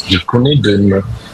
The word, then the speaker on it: je connais deux noms: Je connais deux noms.